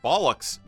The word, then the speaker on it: bollock: Alan Campbell says, a few years ago, I purchased a bollocks dagger. My question is, where on one's person is the proper traditional place to wear a bollocks dagger? Bollock is how I usually hear it pronounced.